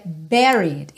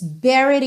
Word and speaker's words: buried: Aí agora aqui uma super pegadinha, porque a gente tem essa palavra com U, que é o enterrado, mas a pronúncia dela é buried, it's buried in my soul, não é bu nem bu, ok?